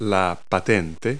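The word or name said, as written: license plate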